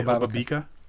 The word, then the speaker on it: Obabika: I call it Obabika, but I'm not sure what Obabika? The proper way to say it. – Obabika is probably Obabika.